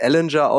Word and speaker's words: Ehlinger: Und da darf jetzt, Detti sagt, er wird Ellinger ausgesprochen, aber ich finde es einfach schöner, ihn Sam Ehlinger zu nennen.